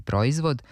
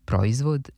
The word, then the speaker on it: proizvod: Ostalo nam je još da kažemo nešto o akcentu imenice proizvod, koja se može čuti i s oblikom proizvod. Ovde nema dubletnosti. Oblik sa kratkosilaznim akcentom na prvom slogu, proizvod, nije normiran.